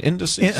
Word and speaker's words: indices: I like the, was it an indices? Am I saying that right? Indices? I should know the plural of index, right? – Yeah, indices. That's exactly right.